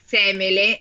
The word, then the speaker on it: Semele: Com'è, comunque, se non è semele, è seme... È seme... Qua, qua è, qua è semele. – Semele? è l'accento sulla E della, di eh, ME. – Sulla seconda E.